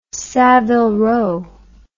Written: savile row

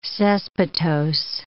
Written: caespitose

